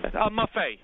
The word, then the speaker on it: Maffei: Maffei. M-A-F-F-E-I.